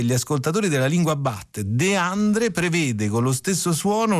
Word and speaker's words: De André: Tutto questo da Achille Lauro in Rolls Royce, sì, ma come De André, anzi, e qui ci aiuterebbe più la grafia della pronuncia, come De Andre.